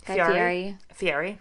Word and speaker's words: Guy Fieri: Guy Fieri? Fieri? – Yeah. – Guy Fieri.